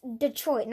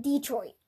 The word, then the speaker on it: Detroit: It's Detroit, not Detroit.